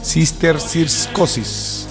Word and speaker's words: cisticercosis: Oye, difícil de pronunciar esa enfermedad, pero esa es cisticercosis, así tal cual.